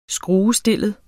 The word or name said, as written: skruestillet